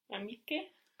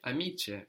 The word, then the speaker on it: amiche: Amiche. – Amiche. – Amiche. – Amiche. Amiche is spelled A-M-I-C-H-E.